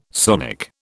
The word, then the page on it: sonic: British English